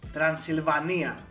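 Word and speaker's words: Τρανσυλβανία: πώς να το πω τώρα, ήταν από την Τρανσιλβάνια η, η Λάουρα. – Τρανσιλβανία. – Τρανσιλβανία, συγγνώμη, συγγνώμη, το είπαμε την αγγλική προφορά.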